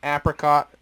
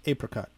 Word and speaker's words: apricot: So he hops this IPA with Simcoe and then conditions it on apricot, mango, and white peach. You don't see a whole lot of white peach. – I believe it's pronounced apricot. – Apricot, sorry. And then we're going to close out with a big dog.